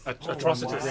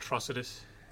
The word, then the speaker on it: Atrocitus: Atrocitaurus. – Atrocious. – Atrocitus. – Wow. – Yeah. – It looks good too. – Yeah. Yeah, he was cool. – Good old Atrocitus.